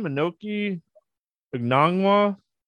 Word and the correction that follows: Okongwu: I know I totally butchered his name, and I'm sorry, but Okongwu? You talking about Okongwu? – Yeah, that's, there you go.